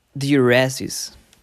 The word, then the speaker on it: diuresis: Alright, forced diuresis. Am I saying it right? Let's double check. – Diuresis. – Oh my goodness.